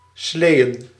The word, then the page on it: sleigh: /sleɪ/